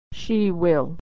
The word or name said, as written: she will